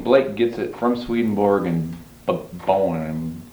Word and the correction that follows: Böhme: however you pronounce it, Jacob Böhme? – Yeah, that's it.